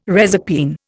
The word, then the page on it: reserpine: Indian